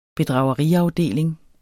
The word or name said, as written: bedrageriafdeling